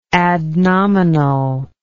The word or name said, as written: adnominal